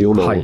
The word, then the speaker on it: YONAWO: YONAWO でヨナオって読むと思うんですけども、